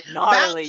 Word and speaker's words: gnarly: Oh, the G is silent. – Gnarly.